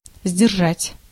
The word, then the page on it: сдержать: [zʲdʲɪrˈʐatʲ]